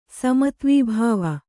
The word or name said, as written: ಸಮತ್ವೀಭಾವ